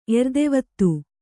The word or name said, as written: ಎರ್ದೆವತ್ತು